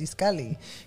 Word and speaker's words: Itzcalli: You know, you're like, no, my name is Itzcalli, you know, and that's it, you know?